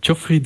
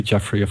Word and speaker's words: Jeffrey: Jeffrey Desmet. – Okay. – But all the English people say Jeffrey, of course, because that's the English way to pronounce it.